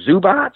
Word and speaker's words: Zubac: I think that I'm going to mispronounce his name. Is it Zubac? – Yeah, that's right.